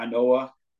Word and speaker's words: Inoue: Speaking of Japan, Inoue. Did I say that right? – Yeah, Inoue.